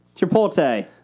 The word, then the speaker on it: chipotle: Chipotle. – Okay. – What did I say? – Chipotle? – How do you say it? – Chipotle? – Chipotle. – You're putting the T and the L on the...